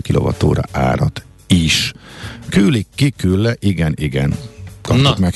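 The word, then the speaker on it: kyllä: A betű umlauttal a vége, K, Y, L, L, A. – Hát ez a külle.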